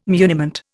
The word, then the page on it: muniment: Indian